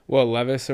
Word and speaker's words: Levis: Like, I don't see how – what's his name? Will Levi? – Will Levis or whatever, yeah. – Yeah, there we go. Sorry for that mispronunciation.